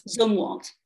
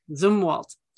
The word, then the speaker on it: Zumwalt: Am I saying that right? Zumwalt? – Zumwalt. – Zumwalt. – Yes.